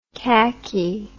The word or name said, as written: cacky